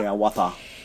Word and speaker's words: Hiawatha: Sie entdecken ähm, eine medizinische Fregatte und zwar die USS ähm, Hiawatha, würde man sie so aussprechen? Hiawatha? – Hiawatha.